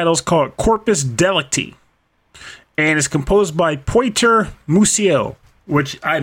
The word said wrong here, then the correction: Piotr: You were just listening to Corpus Delicti from the game Beat Cop, composed by I'm actually going to change my attempt at this because it's spelled P-I-O, so Piotr Musiel.